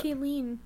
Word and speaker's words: Ghislaine: Ghislaine. You guys say her name wrong.